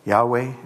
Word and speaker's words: Yahweh: Yahweh is one way of pronouncing it.